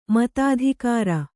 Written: ಮತಾಧಿಕಾರ

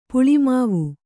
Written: ಪುಳಿಮಾವು

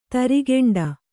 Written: ತರಿಗೆಂಡ

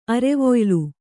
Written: ಅರೆವೊಯ್ಲು

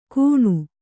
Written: ಕೂಣು

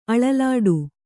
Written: ಅಳಲಾಡು

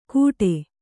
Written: ಕೂಟೆ

